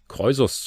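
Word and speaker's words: Kroisos: Ist es auch eine Zeit lang her, aber nicht ganz so lange her, das ähm, spricht man dem König Kreusos zu oder Krösus, wie es eingedeutscht heißt,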